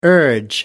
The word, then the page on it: urge: American English